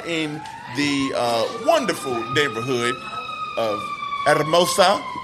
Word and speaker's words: Hermosa: The thing is, I know the H is silent. Mm-hmm. That's all I know now. – Hermosa. – And yeah, you got to roll your R. Yeah, roll them damn R's.